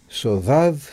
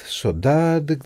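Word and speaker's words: Sodade: Και τίτλος είναι Σοδάδ, Σοντάδ, δεν ξέρω, κάπως ανάμεσα σε ντί και δέλτα το προφέρουν, δεν μπορούμε να το πετύχουμε.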